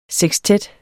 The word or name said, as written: sekstet